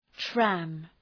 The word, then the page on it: tram: træm